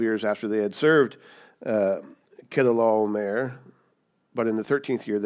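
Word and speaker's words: Chedorlaomer: And it's also known as the slaughter of Kedorlaomer, which is how you pronounce that name, which is really difficult to pronounce.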